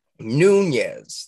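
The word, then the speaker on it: Nunes: Speaking of Congress and all that good stuff here, Nunez, that's how you pronounce that one, right? – Yeah.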